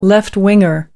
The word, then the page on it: left-winger: American English